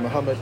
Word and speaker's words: Muhammad: Zaid, Z is in zebra, A-Y-I-D. – Oh, okay. – And Muhammad, like Muhammad Ali.